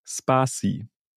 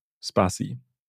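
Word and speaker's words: Spatie: Ich war auf deren Website und die sagen selbst, Sparti is Dutch for space. The character between two words, you pronounce it Sparti. Also es heißt Sparti.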